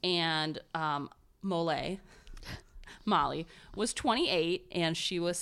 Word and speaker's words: Molei: I am doing the story of Molly Wright. And when I first read this story, I thought her name was Mole. And I say that because it's spelled M-O-L-E-I.